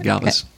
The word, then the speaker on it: Galvus: and Europe for a new diabetes drug called Galvus. Am I pronouncing that right? – Yes, you are. Vildagliptin, Galvus.